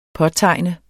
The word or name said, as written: påtegne